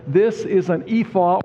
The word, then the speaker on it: ephah: Verses 5 and 6 speaks of a measuring basket, or your Bible might say ephah. That's how you pronounce it.